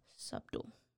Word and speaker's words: subtle: Subtle. – There's, you don't pronounce the B.